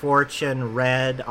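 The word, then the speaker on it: Fortune Redd: And if you, that's a play on words, because Fortune Red, R-E-A-D, but it's spelled R-E-D-D.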